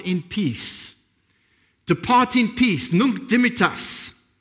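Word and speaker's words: Nunc Dimittis: And it is Nunc Dimittas. I had to Google the pronunciation, so I'm pretty sure I got that right.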